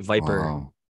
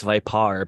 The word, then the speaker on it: Vipar: they're phonetically spelling it Viper, but it's Vipar because it's like par, like the photosynthetic active radiation.